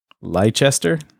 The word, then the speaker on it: Leicester: It began in, I don't know how to spell, how do you say L-E-I-C-E-S-T-E-R? Leicester? – Yeah, Leicester.